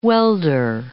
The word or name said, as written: welder